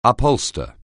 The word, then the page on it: upholster: anglais